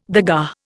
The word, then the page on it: dagga: Indian